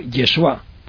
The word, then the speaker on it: Yeshua: Josué es lo mismo, y al mismo tiempo era como una abreviatura de Yahoshua, que en el tiempo de Jesucristo se solía pronunciar Yeshua.